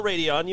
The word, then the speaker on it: Radeon: All right, so Radeon RX, I said that right, right? Radeon? – Radeon. No, Radeon, you had that.